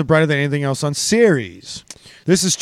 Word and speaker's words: Ceres: It's not serious. – Am I saying that wrong? – It's Ceres.